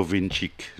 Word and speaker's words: Vinčić: Et l'arbitre de la finale est le Slovène Slavko Vinčić. J'espère que je le prononce bien. – Oui, c'est très très, très bien.